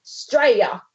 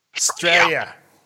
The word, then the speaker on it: Australia: Now, if you're going to say it like that, it's got to be like, you know, when you say America, you've got to just drop the front and it becomes Australia. – Yeah, Australia. You gotta say it like Straya. – Straya.